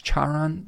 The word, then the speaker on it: Charon: like I believe it's pronounced Chiron, or it's not Chiron, it's Charon, the guide of souls to the underworld,